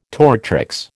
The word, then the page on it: tortrix: American English